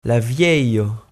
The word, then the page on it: la vieio: provençal